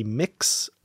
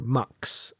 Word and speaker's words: Mx: Apparently, there are a couple pronunciations. It can be Mix or Mux, apparently, for that.